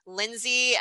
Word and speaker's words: Lynsay: My IG handle is at Lindsay, and my first name is spelled LYNSAY Christine, which is my middle name, CHRISTINE.